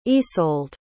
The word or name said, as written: Ysolde